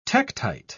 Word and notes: tektite: British English

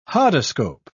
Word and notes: hodoscope: British English

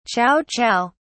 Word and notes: chow chow: British English

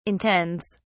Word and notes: intends: British English

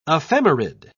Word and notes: ephemerid: British English

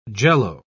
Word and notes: Jell-O: British English

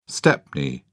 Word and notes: stepney: British English

